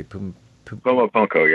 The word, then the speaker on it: Puma Punku: I think, is it Puma Punku? I don't know how to pronounce that exactly. – Puma Punku, yes.